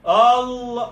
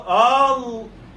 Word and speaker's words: Allah: Kaže Allah, Allah, a izgovara, znači, prilikom izgovaranja Allahog imena izgovara se a, niti se ne izgovara ono čisto e, nego je glas između a i e.